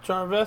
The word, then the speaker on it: Gervais: So, Afterlife is a black comedy drama web television show starring Ricky Gervais. Is that how you pronounce his last name? – Yeah.